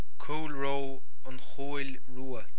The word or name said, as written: An Chúil Rua